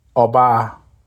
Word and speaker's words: oba: Oba. The sound focus is on the open O and the long A.